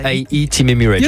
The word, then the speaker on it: UAE Team Emirates: UAE Team Emirates. J'aurais dû prononcer comme ça.